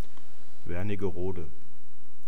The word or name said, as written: wernigerode